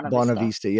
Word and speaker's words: Bonavista: Cause when your album came out, it's Bonavista, I guess is how you pronounce it, right? – Bonavista.